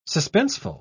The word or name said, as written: suspenseful